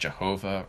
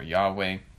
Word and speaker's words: Yahweh: But the Lord, not just a generic Lord, this is again all caps, that means it's the name of God, sometimes pronounced Jehovah or Yahweh.